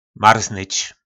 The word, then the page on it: Marznyč: Po našimu